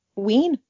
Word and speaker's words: Ween: W-E-E-N. – Like a peen, but ween? – Yeah.